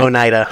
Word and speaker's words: Oneida: He just had like a Oneida, I think that's how you say it. – Oneida. – Yeah. Close enough.